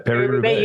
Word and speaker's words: Paris-Roubaix: I always say it wrong. Paris-Roubaix. – I think you got it. Paris-Roubaix. – You did. Okay. You got it right.